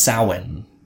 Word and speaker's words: Samhain: S-A-M-H-A-I-N, but it's ancient Celtic, so it's not pronounced like that. It's Samhain. Or Samhain? I've heard it pronounced Samhain as well.